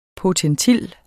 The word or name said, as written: potentil